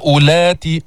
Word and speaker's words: أولات: وإن كن أولاتي أولات، هذه الواو لا تلفظ، انظر عليها علامة، هذه الدائرة علامة على زيادة الحرف وعدم النطق به، فما بتقول أولاتي، ماذا تقول؟